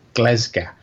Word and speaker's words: Glasgow: For the record, the pronunciation of the Scottish city is Glasgow. Right, the first vowel is an e, as in ten pence. So it's gle.